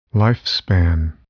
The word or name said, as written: lifespan